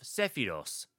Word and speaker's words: Sephiroth: Constant cries of Sephiros, the Japanese pronunciation of Sephiroth by the choir,